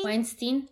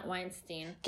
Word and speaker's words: Weinstein: auch zum Beispiel wie spricht man denn aus? Weinstein? – Weinstein, ja, Weinstein.